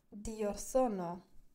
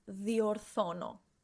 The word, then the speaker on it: Διορθώνω: Διορθώνω. Did I say this correctly? – Yeah, it's softer the delta. Διορθώνω.